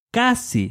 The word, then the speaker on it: casi: In order to say almost always, in Spanish I have to add the word casi, which means almost, casi. This is spelled C-A-S-I, casi.